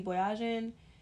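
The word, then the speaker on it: Boyajian: It's B-O-Y-A-J-I-A-N. Basically Kardashian with the B.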